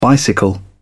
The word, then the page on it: bicycle: British English